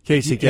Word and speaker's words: Kasich: And it is pronounced Kasich? – Kasich, yes. – With a K?